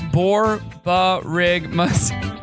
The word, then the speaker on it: borborygmus: No, it says it's pronounced as borborygmus.